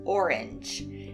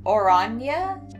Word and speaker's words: Oranje: It's apparently not said like orange. It's something like Oranje, Oranje, something like that. Either way, it's spelled like orange, but it's pronounced way cooler.